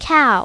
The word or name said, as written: cow